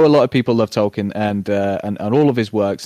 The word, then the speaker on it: Tolkien: Yeah, I'm going to go with the Rings trilogy, because as much as people may love Tolkien, sorry, did I say that right? – What, Tolkien? Yeah, absolutely. Yeah, you said that right.